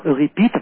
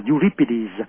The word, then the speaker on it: Euripides: người Thiết Khuê cổ đại là Euripides, tiếng Pháp đọc là Euripides và tiếng Anh là Euripides, tiếng Hy Lạp là Euripides, viết về đề tài của Medea.